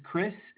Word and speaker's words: Chris: It's Chris, C-H-R-I-S. – And your last name? – Cortes, C-O-R-T-E-S, but it might be important to note that it was purchased by Patrick Scheel.